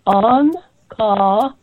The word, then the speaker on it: Ankahara: Ankahara. All the A's are open. As in Italian opera singing, you cannot sing ka, but you can sing ka, ah.